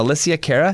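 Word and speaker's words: Alessia Cara: And then How Far I'll Go, and this is from Moana, that movie, by that pop girl, Alicia Cara.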